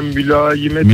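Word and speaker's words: mülayemet: E, Mülamiyet diyeceğim, mülayimet.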